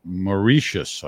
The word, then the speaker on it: Mauritius: If you're interested, it's pronounced Mauritius.